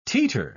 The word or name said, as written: teeter